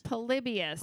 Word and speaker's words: Polybius: Polybius? – Polybius.